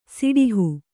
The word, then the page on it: ಸೆಡಹು: seḍahu